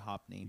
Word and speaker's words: Hophni: So when so what happened is that basically the sons of Eli, and I can't pronounce it's Phineas and Hophni. – Yeah, Phineas and Hophni.